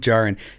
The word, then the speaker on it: Djarin: Din Djarin. Yeah, but it's spelled Djarin. – Yeah, the D's silent, I think. – Yeah, you would like a silent D.